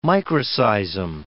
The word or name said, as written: microseism